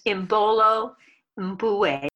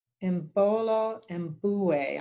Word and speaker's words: Imbolo Mbue: Well, her name is Imbolo Mbue. – Imbolo Mbue.